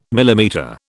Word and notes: millimetre: British English

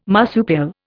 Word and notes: marsupial: Indian

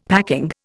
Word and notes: packing: Indian

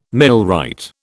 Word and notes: millwright: British English

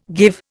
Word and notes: give: Indian

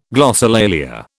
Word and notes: glossolalia: British English

